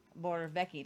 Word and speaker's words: Morawiecki: The Polish prime minister said, Prime Minister, Morawiecki. And we had to learn how to pronounce some things in Polish. Morawiecki. It doesn't look like Morawiecki. That's how it's said, pronounced.